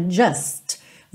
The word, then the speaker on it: just: É muito comum na palavra just você não pronunciar essa letra T. Você dá uma alongadinha no S e já junta na próxima palavra.